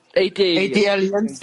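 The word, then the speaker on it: ATLiens: Yeah, it's because of the ATL. Aliens, yeah. Atlanta and then Aliens. I always struggle to pronounce it. Okay, so now, thank you for Aliens. – Aliens.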